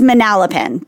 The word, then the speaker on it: Manalapan: Is that how you pronounce it? I always thought it was Manalapan. – Oh, Manalapan? You would know. You're more Jersey than I am. – It's Manalapan. But I love creative rendition Manalapan.